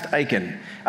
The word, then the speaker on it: Isken: It's spelled I-S-K-E-N, but it's pronounced Iken.